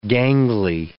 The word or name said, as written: gangly